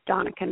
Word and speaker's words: Donica: So you just go to Google and you type in Love Enterprise, or you can type in my name, Donica Knight, and you spell it DonicaKnight, like Monica with a D in front.